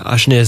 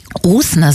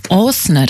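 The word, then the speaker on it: Åsne: In ihrem Sachbuch Zwei Schwestern im Bann des Dschihad zeichnet Ashne Seierstadt, ist das richtig ausgesprochen? – Osne sagt man tatsächlich. – Wie sagt man? – Osne, das ist so eine Kugel über dem A.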